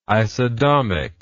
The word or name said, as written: isodomic